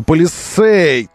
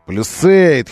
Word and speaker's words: Palisade: В компании называют его вот на манер американский. Причем это произношение само по себе чужеродно даже для специалистов компании. Поэтому они время от времени говорят Palisade, потом Palisade, потом снова Palisade и снова Palisade.